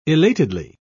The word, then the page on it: elatedly: British English